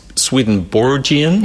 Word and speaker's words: Swedenborgian: I have so many questions, okay, as many people do, about what's Swedenborgian, is the right pronunciation. – Yes.